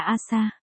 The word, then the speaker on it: Asa: PS, trong tiếng Nhật buổi sáng phát âm là Asa.